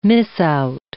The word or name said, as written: miss out